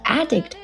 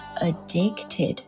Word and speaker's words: addict: You're the drug that I'm addicted to, and I want you so bad. 你是让我成瘾的药，我对你呢无比渴望。来看两个单词，先看第一个 addict，第二个呢是 bad，我们稍后讲。 Addict 其实是刚刚歌词里面的那个 addicted，它的名词形式啊。动词的时候呢，它读作 addict。